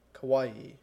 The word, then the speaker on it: Kauai: Kauai.